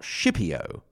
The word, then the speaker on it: Scipio: Also, there's an interesting note on pronunciation, because we do pronounce Scipio and not Scipio. This is what we call pronuncia latina restituita. I'm not sure what that is called in English, restituted or reconstructed or something like that, and it is supposedly the way the Latins actually pronounced it themselves, that is, k instead of sh, basically.